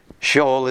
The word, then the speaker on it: Sheol: Sheol is the Hebrew word, pretty much the same, pronounced exactly the same.